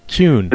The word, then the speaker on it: Kuhn: Well, again, if I'm German, if my main background is German, German pronunciation of K-U-H-N is Kuhn. – Kuhn. – The Jewish pronunciation is Kuhn. – Kuhn. – So I'm not sure what I am, so you can call me whatever you want.